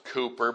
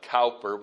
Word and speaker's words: Cowper: William Cowper, his name is usually pronounced Cooper, but it looks like Cowper when you read it.